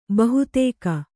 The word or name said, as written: ಬಹೂದಕ